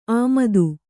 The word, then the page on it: ಆಮದು: āmadu